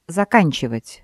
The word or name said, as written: заканчивать